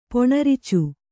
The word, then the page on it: ಪೊಣರ್ಚು: poṇarcu